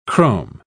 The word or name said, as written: chrome